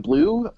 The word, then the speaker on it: Bleau: Blue. Really helped us out there. Blue is spelled BLEAU, by the way.